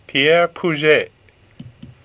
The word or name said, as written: Puget